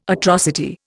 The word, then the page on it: atrocity: Indian